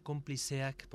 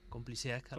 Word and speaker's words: Konplizeak: Sí, se pronunciaría Compliceac porque esa Z tendría un poco, estaría más cercana a la S que a la Z Compliseac, algo así. – Compliseac. – Eso es, está muy bien, así está muy bien.